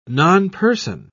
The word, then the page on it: nonperson: British English